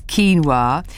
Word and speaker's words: quinoa: As we used to say when we first saw, well, actually, producer Bex used to call it quinoa. I think she still does, maybe. But anyway, it's quinoa.